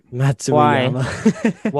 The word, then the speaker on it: Matsuyama: Matsuyama.